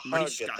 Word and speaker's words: Mariska: I'm pronouncing her name right, Mariska? – Mariska Hargitay.